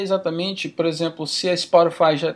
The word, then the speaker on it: Spotify: Que eu nem sei se essa é a pronúncia correta em português, mas Spotify. – É, isso mesmo.